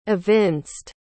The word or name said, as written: evinced